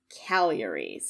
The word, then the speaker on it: Cagliari: It's the one that looks like Cagliari, but apparently it's Cagliari, so just pointing that out now, pointing out my own flubs in past episodes.